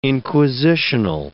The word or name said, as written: inquisitional